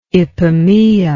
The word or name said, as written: ipomoea